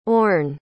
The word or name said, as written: orne